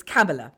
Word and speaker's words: Kamala: The wonderful vice president-elect, we were ticked off by Kathy, 52 and 11 months, who I think lives in the States, and apparently it rhymes with Pamela. So it's Kamala, okay? – Kamala.